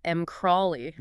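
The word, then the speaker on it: MCrAlY: And funnily enough, material scientists actually pronounce that acronym MKRALY.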